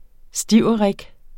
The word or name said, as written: stiverik